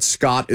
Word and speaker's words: Skjodt: Scott, but Scott is spelled S-K-J-O-D-T.